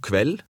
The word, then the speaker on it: kveld: Read number 8, pronouncing the letters KV separately. – God kveld.